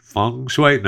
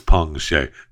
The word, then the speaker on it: Peng Shuai: I think it's Peng Shuai. Is it Feng Shuai? No, it's Peng Shuai. P-E-N-G-S-H-U-A-I, tennis star from China.